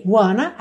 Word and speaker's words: wanna: Wanna, a gente já falou disso lá na parte 1. Então, sempre que aparecer aqui want to, você já sabe que a pronúncia vai ser wanna.